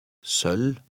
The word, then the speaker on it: Sølv: Remember the silent V. – Sølv.